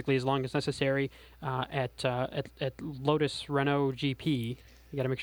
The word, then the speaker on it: Renault: It's now pronounced Renault, by the way. That's one thing. It's Renault.